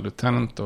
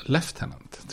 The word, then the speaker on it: lieutenant: Det uttalas olika i Storbritannien och USA. I USA säger man lieutenant och i, eh, och i Storbritannien lieutenant tror jag.